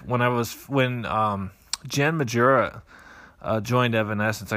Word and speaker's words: Majura: It's pronounced Majura. And I go, well, you know, since you're the source, I have to believe you.